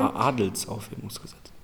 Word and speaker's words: Adelsaufhebungsgesetz: Okay, dieses Adelaufhebungsgesetz, habe ich das richtig gesagt? Ich glaube schon. – Adelsaufhebungsgesetz.